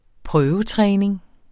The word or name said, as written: prøvetræning